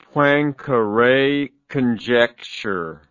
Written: poincare conjecture

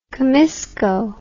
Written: comisco

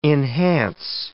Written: enhance

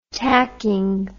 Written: tacking

